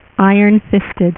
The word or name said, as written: Iron Fist